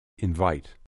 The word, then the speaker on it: invite: Invite. Invite. Invite. I'll invite you. – برای اینکه تلفظش با لغت سفید قاطی نشه، دقت کنید که باید دندونای بالا یک کمی با لب پایین تماس پیدا کنه.